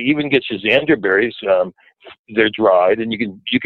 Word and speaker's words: schizandra: So anyway, schisandra is like a super, super cool herb. It's spelled S-C-H-I-Z-A-N-D-R-A, and schisandra.